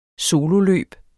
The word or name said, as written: sololøb